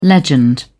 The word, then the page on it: legend: British English